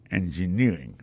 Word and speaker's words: engineering: electrical engineering, engineering, ça l'accent tombé les mêmes sur avant dernière syllabe là.